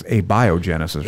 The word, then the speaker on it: abiogenesis: Are you familiar with abiogenesis? – Abiogenesis. – Abiogenesis. – I think it's abiogenesis, right? – Abiogenesis. – I've been mispronouncing it all these years.